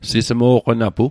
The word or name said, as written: sismo'qonapu